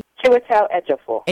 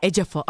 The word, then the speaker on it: Chiwetel Ejiofor: Chiwetel Ejiofor, did I say that correctly? – Chiwetel Ejiofor. – Ejiofor. Oh, you're gonna say it better regardless.